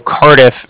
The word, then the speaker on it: Cardiff: Again, the Bank of Cardiff is spelled C-A-R-D-I-F-F, as in Frank Frank, so it's like car and then different.